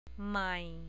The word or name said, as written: mai